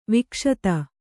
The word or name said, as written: ವಿಕ್ಷತ